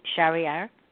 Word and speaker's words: Charrier: Gemma Smith and Grace Chariar.